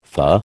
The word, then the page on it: fah: American English